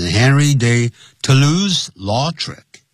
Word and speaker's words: Toulouse-Lautrec: 好、啊，通常呢，就是直接也就会念成 Henry de Toulouse-Lautrec，这是英文的念法，Henry de Toulouse-Lautrec captured it，他就捕捉到了这个画面了，在什么地方呢？In many of his paintings，在他许多的画作里头啊，他都有呢，把它这个画上去。